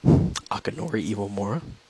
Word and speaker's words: Akinori Iwamura: Akinori Iwamura.